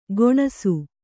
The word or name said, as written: ಗೊಣಸು